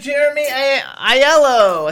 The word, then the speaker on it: Jaremy Aiello: Mr. Jeremy Aiello.